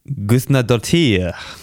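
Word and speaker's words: Guðnadóttir: Hilda Gusnadottir.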